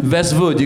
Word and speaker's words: Vestvood: Is that how you pronounce it? – Yeah, Vestvood? – Vestvood, you got it right.